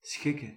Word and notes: schikken: /ˈsxɪ.kə(n)/

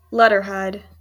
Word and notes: letterhead: /ˈlɛtə(ɹ)ˌhɛd/

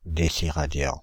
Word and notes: déciradian: Paris; [de.si.ʁa.djɑ̃]

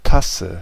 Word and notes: Tasse: /ˈtasə/

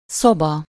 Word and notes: szoba: /ˈsobɒ/

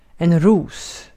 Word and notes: ros: /ruːs/

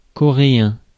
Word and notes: coréen: [kɔ.ʁe.ɛ̃]; /kɔ.ʁe.ɑ̃/; France

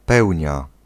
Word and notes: pleine lune: /plɛn lyn/; France (Île-de-France)

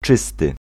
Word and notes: blanc: France; [blɑ̃]

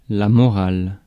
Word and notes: morale: [mɔ.ʁal]; France

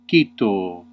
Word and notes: cito: Latin

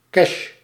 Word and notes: espèces: /ɛs.pɛs/

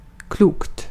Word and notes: klokt: svenska

